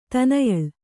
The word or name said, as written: ತನಯಳ್